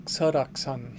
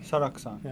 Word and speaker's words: Seoraksan: Seoraksan. – Seoraksan.